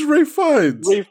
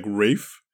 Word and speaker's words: Ralph: By the way, me had this thing recently where apparently someone's name who's Ralph, R-A-L-P. – It's not Ralph. – Okay, tell them. – It's Ray Fiennes. – Okay, why is his name Ray Fiennes when it's spelled Ralph? – I don't know. That's how everybody just pronounces it. I don't know what to say to you. – Anyway, small detail. – To be fair, I've always pronounced it as like Ray Fiennes as one word. – Okay. – Like, I think pronouncing the word itself is like Rafe.